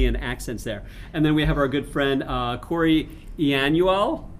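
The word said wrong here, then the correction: Ianuale: Close enough. – How would you say it? – Ianuelli. – Say it again. – Ianuelli. – Ianuelli. It's I-A-N-U-A-L-E for those of you who want to connect with him on LinkedIn.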